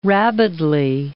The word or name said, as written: rabidly